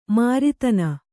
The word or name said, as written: ಮರುದಿನ